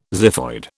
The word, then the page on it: xiphoid: British English